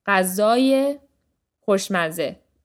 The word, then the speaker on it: غذای خوشمزه: So the emphasis here isn't on the ye sound, it's on the second syllable of غذا. غذای خوشمزه.